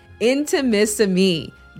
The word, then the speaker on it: Intimissimi: But the name can be a little challenging to pronounce. Mississippi. It is Intimissimi, the art of Italian lingerie.